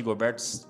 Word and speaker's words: Gobert: Até o Vinícius Soares mandou aqui, Gobert, certeza, sem erro.